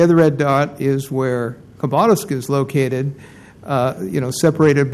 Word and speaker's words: Khabarovsk: And the KH, the beginning of Khabarovsk, is a H sound, and the stress is on the middle syllable, so it ends up Khabarovsk, Khabarovsk.